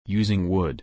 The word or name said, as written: using wood